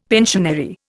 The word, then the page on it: pensionary: Indian